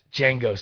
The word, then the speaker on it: Django: Yes, Django style. The D is silent.